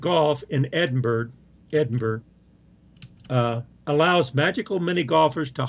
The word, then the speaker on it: Edinburgh: Edinburgh. – Edinburgh. – Yeah. – Edinburgh is Burra.